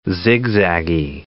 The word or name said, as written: zigzaggy